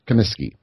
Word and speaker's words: Comiskey: Kaminsky, is that how he pronounces his name? Comiskey. – Comiskey, right? Okay.